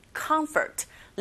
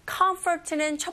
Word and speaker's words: comfort: Perhaps glistening comfort. Listening 앞에 G가 붙어서 glistening이에요. Perhaps glistening comfort. Comfort는 첫 번째 음절에 강세가 있고 F 발음하실 때 comfort 하시면 안 되죠. Comfort 긁어주세요. 윗니로 아래에서 살짝만 닿으면서 이렇게 긁어주시면 됩니다.